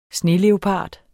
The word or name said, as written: sneleopard